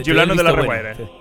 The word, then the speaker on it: Giuliano della Rovere: Aquí entra el nostre protagonista, el cardenal Giuliano della Rovere. No sé si... Com ho pronunciaríeu millor, això? – Bien. – Sí? Giuliano della Rovere.